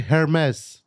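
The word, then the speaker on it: Hermès: Hermes. That's the right way to say it.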